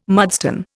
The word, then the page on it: mudstone: Indian